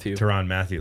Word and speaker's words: Tyrann Mathieu: Tyrann Mathieu.